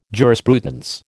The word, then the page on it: jurisprudence: American English